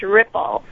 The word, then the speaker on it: RRPHL: We have the a Rockford Regional Partnership for Health Literacy, acronym RRPHL, pronounced RIPPLE.